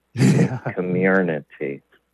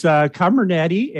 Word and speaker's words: Comirnaty: Comirnaty. – Comirnaty.